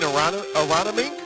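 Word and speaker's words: Aronimink: You mean Aramink? Aramink. I believe I learned how to say it correctly.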